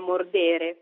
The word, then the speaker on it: mordere: Per esempio, se in italiano diciamo mordere, il verbo latino è invece mordere con la E lunga, ridere invece di ridere, oppure verbi come persevero in italiano, che in latino però si pronuncia persevero, o preparo e preparo.